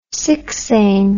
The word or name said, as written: sixain